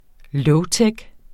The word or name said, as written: lowtech